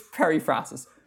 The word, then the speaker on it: periphrasis: Apparently, or not even apparently, the word periphrasis is not pronounced periphrasis.